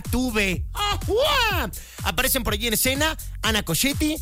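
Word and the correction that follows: Ana Ciocchetti: Isabela Camil, Ana Sciocchetti, perdónenme, lo dije mal. Ana Sciocchetti, Isabela Camil y Sabine Mussier.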